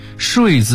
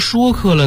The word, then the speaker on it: 说客: 上学的时候语文课本里有个出自战国策的名篇触龙睡赵太后，触龙的身份呢是个说客，睡字跟游说里头是一个读音，当年老师还千叮咛万嘱咐的一定不能给读错了，那怎么现在就变成说客了呢？